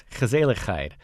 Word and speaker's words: gezelligheid: The word is gezelligheid, and it's as hard to pronounce uh, as it is easy to understand once you've experienced it.